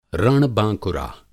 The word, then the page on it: रण-बाँकुरा: Hindi